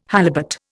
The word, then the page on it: halibut: Indian